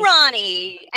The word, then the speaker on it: Ronie: It is spelled R-O-N-I-E, and it is pronounced, tell us. – Ronnie. It's Ronnie. – Ronnie.